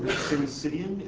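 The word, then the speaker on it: syncytium: So this is, did you call it Simsidium? Is that how it's pronounced? – Yeah.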